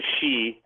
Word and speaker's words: sidhe: they call them the she, spelled S-I-D-H-E, pronounced S-H-E-E, the she would come in and change their babies.